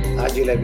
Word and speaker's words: Agile: Agile because it's an Italian company and that's how we pronounce agile.